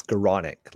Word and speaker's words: Skowronek: It's Skowronek, ladies and gentlemen. Skowronek. Ben Skowronek. – Skowronek. – Skowronek, yes.